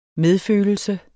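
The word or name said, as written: MEDFØLELSENS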